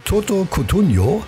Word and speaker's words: Toto Cutugno: So, und am allerliebsten, schreibt die Corinna, hört sie von Toto Cutugno.